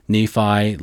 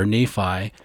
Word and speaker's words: Nephi: Whenever we're in the Arabian Peninsula and talking about Lehi or Nephi, we always use their pronunciation of Lehi and Nephi. That is because Semitic names usually put the emphasis on the last syllable.